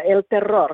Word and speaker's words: El Terror: You know, yeah, El Terror. You got to roll your R's.